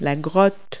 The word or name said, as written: cave